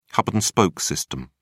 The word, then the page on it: hub-and-spoke system: British English